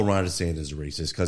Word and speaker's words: DeSantis: Can you imagine if DeSantis, was that how you say his name? – Ron DeSantis.